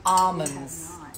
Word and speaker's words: almonds: We usually we pronounce them almonds. I think that's how everybody pronounces it. Almonds, I'm saying the L.